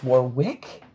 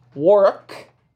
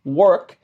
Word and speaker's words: Warwick: And that's written as though it's Warwick, but it is pronounced Warwick. Warwick.